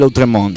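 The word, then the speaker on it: Lautréamont: El Conde de Lautremont.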